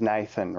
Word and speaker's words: Nathan: So, and my speech impediment was I used to pronounce my THs like an F. Nathan. And when people, like when I'd say it for short, I'd just say, yeah, Nafe.